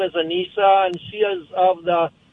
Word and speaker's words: Aneesa: Her name is spelled A-N-E-E-S-A. Her name is Anissa, awesome. – Like Lisa, but instead of Lisa, it's called Anissa. It's an Arabic name.